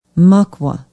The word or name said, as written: mukwa